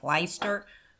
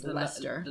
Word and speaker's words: Leicester: I always pronounce this wrong, and you say it's really funny. I'm like, Leicester? But apparently it's Leicester.